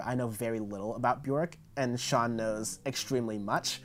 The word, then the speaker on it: Björk: But I remember in an interview she said it's Björk, like jerk, which is really funny. – It's spelled B-J-O-R-K.